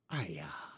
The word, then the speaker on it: Aia: They never say it, but I'm going to say Aya. It's spelled A-I-A.